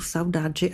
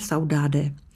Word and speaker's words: Saudade: Má se to vyslovovat opravdu Saudade a ne Saudade.